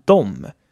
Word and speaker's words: de: In der gesprochenen Sprache sagt man in diesem Fall dom, wie ich euch früher beigebracht habe, kann man es auch so aussprechen, wie es geschrieben wird, aber gerade in dem Fall mit Dom fließt die Sprache besser, als wenn man D sagen würde.